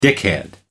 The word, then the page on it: dickhead: American English